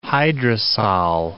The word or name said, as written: hydrosol